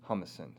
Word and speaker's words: Humason: Now, let's talk about his right-hand man, Milton Humason.